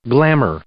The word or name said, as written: glamour